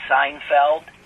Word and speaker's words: Seinfeld: I photographed Jerry Seinfeld.